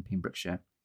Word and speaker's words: Pembrokeshire: The weather cleared as I reached the top of St. Anne's Head in Pembrokeshire.